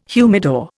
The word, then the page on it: humidor: Indian